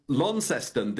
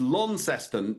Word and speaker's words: Launceston: Now, I was told when I was in Launceston that you've got to hit it's Launceston, not Launceston. Is that correct? – Lawn, yeah, got to say lawn.